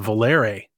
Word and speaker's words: Volare: It was Volare, actually, I believe is how it's pronounced.